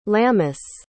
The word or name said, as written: lamis